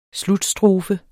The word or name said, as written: slutstrofe